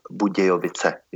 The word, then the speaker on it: Budějovice: The Czech version of Budweiser Budvar is Budějovický Budvar, as the city of Budweiser is called Budějovice in Czech.